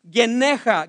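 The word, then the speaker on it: geneja (γενεά): The word generation in the Greek is pronounced geneja, geneja.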